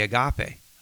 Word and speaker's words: agape: It's the agape. I, and I don't say it right. Agape. You've got to clear your throat when you speak Greek.